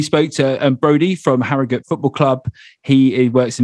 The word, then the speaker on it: Harrogate: it, it is Harrogate. That's the, the proper note to pronounce it. – All right, says the Yorkshire man. It's Harrogate, according to Freddie.